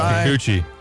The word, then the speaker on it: Kikuchi: How do you say that? – You say Kikuchi. – Kikuchi. – Yep.